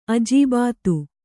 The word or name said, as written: ಅಜೀಬಾತು